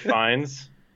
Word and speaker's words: Fiennes: Ralph Fiennes. – Hey, I like how you said it right this time.